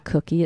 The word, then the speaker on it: koekje: The word cookie comes from a Dutch word that sounds like cookie. It's spelled K-O-E-K-J-E, and it means little cake.